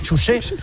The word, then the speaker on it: Chuse: ¿Pero cómo se pronuncia este José? – José. – No, José no. – José porque sería CH. – José. – Como en Fabla, Chuse. Es Chuse. – Chuse. – O sea, CH.